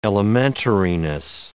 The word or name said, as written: elementariness